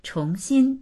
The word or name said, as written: chong2xin1